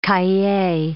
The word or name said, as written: cahier